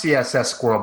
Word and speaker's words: CSS Squirrel: It's pronounced CSS Squirrel by most people for that reason.